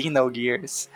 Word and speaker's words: Xenogears: Você sabe, na né, pronúncia do X em inglês ela engana. Tem o som de Z. É Z. – É, Xenogears.